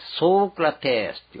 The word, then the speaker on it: ソクラテス: ソクラテスってなってソークラテスっていうわけです。